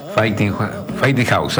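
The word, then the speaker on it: Fighting Hawk: Fighting House, vendría a ser, ¿sí? Fighting House, así se pronuncia.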